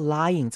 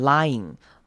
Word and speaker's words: lying: If you're doing anything deceptive or manipulative, or you're lying or hiding something，好，注意到 lying 这个字，大家很常念错，很容易念成 lying，这样就念过去了。但其实 y 跟 i 是两个声音，要各自独立出来念，要念成 lying，那个 ing 前面要一个 y 的声音出现，lying，记得这个字是两个音节。